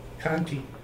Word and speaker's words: Conte: It's spelled C-O-N-T-E, Community School. – Conte. – Conte.